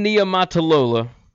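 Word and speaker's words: Niumatalolo: Ken Niumatalola, I said it right.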